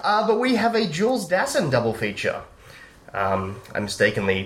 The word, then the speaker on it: Dassin: Jules Dassin.